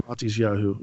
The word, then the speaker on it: Matisyahu: Now that you said that with Matisyahu, is that how you say his name? – Yeah, Matisyahu. Yep.